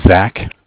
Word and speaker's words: Zach: My name is Zach, spelled Z-A-C-H. – Z-A-C-H? – Correct.